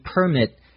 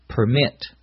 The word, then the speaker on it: permit: Notice when we use it as a noun, the accent is on the first syllable, permit. When we use it as a verb, the accent is on the second syllable, permit.